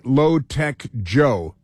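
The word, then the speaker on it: Low Taek Jho: Low-Tech is like Low-Tech Joe, you know, but it's spelled differently. But L-O-W and then T-A-E-K-J-H-O. So he's still Low-Tech Joe, but he's known as Joe Low.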